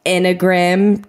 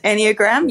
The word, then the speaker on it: Enneagram: So we have both jumped on and we have done the Enneagram test. – Enneagram, yep. – Enneagram. Shit, I didn't pronounce the A.